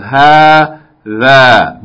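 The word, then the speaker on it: ha, tha (هذا): And this is also a long vowel, we said, in the word hadha, but the alif is not written, right? This is actually pronounced as though there's a long vowel there. Two long vowels. Ha, tha. But it's written like this, without the first alif. But it's pronounced long. Ha, tha.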